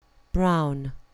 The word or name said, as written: brown